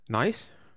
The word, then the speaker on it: Niasse: Men, Nisse, Nisse til Everton, eller Niasse, eller hvordan den udtales? Nice. Nice.